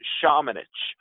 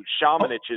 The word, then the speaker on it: Šamanić: It's the same thing as Dario Šarić. It is mm-hmm. Luka Šamanić. So Šamanić is how he pronounces his last name.